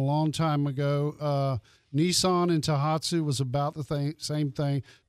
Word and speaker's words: Tohatsu: How does, I hope I pronounce this correctly, Tohatsu, is that correct? – Mm-hmm.